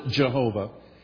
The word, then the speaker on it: Jehovah: In Hebrew, it's Jehovah. We pronounce it Jehovah.